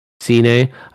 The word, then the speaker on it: Cine: You know, having Lewis Cine, I always say his name right, Cine, I think having him hurt certainly doesn't help.